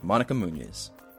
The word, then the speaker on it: Monica Muñiz: All right, settle in, take a deep breath, gently let it go, and enjoy this chat with Monica Muñiz.